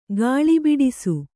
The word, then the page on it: ಗಾಳಿಬಿಡಿಸು: Kannada